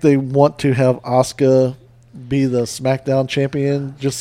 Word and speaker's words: Asuka: That's just Asuka.